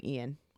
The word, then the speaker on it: Ian: He spells his name Ian, but it's pronounced Ian, just so you know.